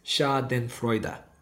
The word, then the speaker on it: Schadenfreude: And it's Schadenfreude. – Schadenfreude. All right. We're all learning. We're all learning something today. – Yeah. So if you've ever seen that word, didn't know how to pronounce it, there it is, at least according to Google Translate.